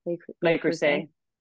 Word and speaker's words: Le Creuset: Yeah, how do you say it? Le Creuset? – Le Creuset. Yeah.